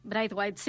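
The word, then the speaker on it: Braithwaite: També segurament entre avui i demà es presentarà el nou fitxatge del Barça, el Bright White, no sé si es pronuncia així. – Bright White, sí.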